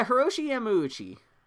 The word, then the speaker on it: Hiroshi Yamauchi: Yeah, Hiroshi Yamauchi.